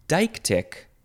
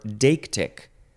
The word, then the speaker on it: deictic: The next word is deictic or deictic. So it is spelled D-E-I-C-T-I-C. So deictic is one way, and then deictic is the other way.